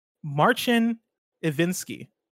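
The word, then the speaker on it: Marcin Iwiński: Uh, Big Bad Beluga writes in and says CDPR head's name is pronounced Marcin Ivinsky.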